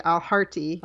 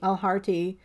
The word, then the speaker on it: Alharthi: I think it's like a hard T, Alharthi. – Alharthi.